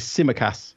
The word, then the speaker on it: Tsimikas: that is Tsimikas, who's playing as we're recording at left back with Robertson injured.